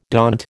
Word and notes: daunt: American English

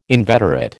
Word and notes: inveterate: American English

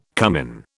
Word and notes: cumin: British English

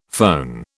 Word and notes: foehn: British English

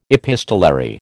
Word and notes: epistolary: American English

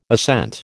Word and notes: assent: American English

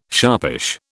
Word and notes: sharpish: British English

